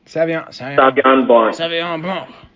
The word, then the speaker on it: Sauvignon Blanc: Savion Blanc.